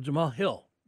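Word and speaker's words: Jamahal Hill: Jamal Hill.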